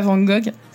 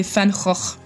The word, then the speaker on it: Van Gogh: Et le peintre Van Gogh aussi se prononce pas Van Gogh. Si vous dites Van Gogh à un néerlandais, il va pas comprendre, c'est Van Gogh.